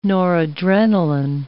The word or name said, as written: noradrenaline